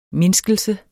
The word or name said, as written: MINDSKELSE